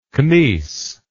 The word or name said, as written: Knies